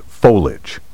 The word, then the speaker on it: foliage: But back to the word verbiage, which is often mispronounced, verbiage, as if it had only two syllables. Carriage and marriage have two syllables, but verbiage and foliage have three.